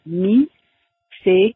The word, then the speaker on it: micellaire: And then the second word, most people in French pronounce it micellaire. Although I have heard lots of people in English say eau micellaire, but technically in French it would be micellaire.